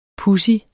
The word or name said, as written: pudsig